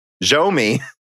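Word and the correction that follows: Xiaomi: And he goes, but it's basically pronounced as Shiao with a flat tone and me. I don't understand, Dan. How do I say this word? – I think a high flat tone. So like Xiao Mi? No, like Xiao Mi.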